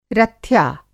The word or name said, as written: रथ्या